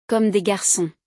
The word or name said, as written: Comme des Garcons